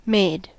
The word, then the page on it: made: American English